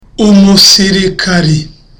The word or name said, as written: umusirikari